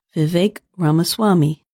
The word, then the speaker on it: Vivek Ramaswamy: The correct way to say his name is Vivek Ramaswamy.